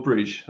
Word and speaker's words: Brugge: Club Brugge, is that how you say it, or Bruges? I say Bruges. – Brugge's better. – I'll say Brugge.